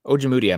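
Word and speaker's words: Ojemudia: and you'll have to help me because the Minnesota Vikings guys were butchering his name too. Oje Mudia? – Yeah, Oje Mudia. Michael Oje Mudia from University of Iowa.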